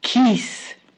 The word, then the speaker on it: Ge'ez: La Biblia etíope tiene mucho que ver con el idioma conocido como Ge'ez, pero digamos, Ge'ez es como se escribe y se pronuncia Kis.